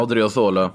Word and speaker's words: Odriozola: Odriozola. – Odriozola som högerback då.